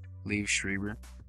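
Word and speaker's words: Liev Schreiber: Um, and how do you pronounce his name? Live? Leave? Leave? – leave Schreiber?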